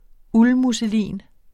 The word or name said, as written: uldmusselin